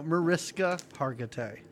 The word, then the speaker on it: Mariska Hargitay: How do you say her name? – Mariskatay Hargitay. No, Mariska Hargitay.